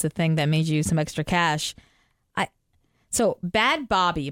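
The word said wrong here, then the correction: Bhad Bhabie: am I saying that right? Bad Bobby, right? She's the Catch Me on the Outside girl from Dr. Phil. – Bad Baby. – Bad Baby? – Yeah.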